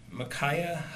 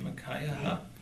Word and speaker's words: Micaiah: Micaiah? I heard it said several times yesterday, and I still struggle. Micaiah? – Isaiah's got the same spelling, and we say Isaiah. – Micaiah?